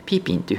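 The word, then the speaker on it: 批评: え批判という言葉はですね、中国語で批評というふうに漢字を日本語で言うと書きまして、え発音としてはですね、批判、批評の批がーというふうに発音します。で、え批評の評という字はピンというふうに発音しますので、ピーピンというふうに言うんですけれども、こちらがですね、批評する、批判するというような意味になります。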